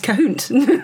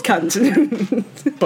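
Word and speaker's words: Khünnt: And these are friends of mine, so it's, you know, the name is pretty much a joke, but it's spelt K-H-U with an umlaut N-N-T, which is, of course, pronounced Kahunt. It's not. They're called cunt.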